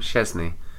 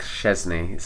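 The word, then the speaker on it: Szczęsny: Chesney. Wojciech Szczesny.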